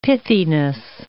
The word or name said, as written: pithiness